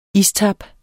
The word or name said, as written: istap